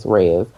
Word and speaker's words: reais: It's spelled real, R-E-A-L, but it's Reais.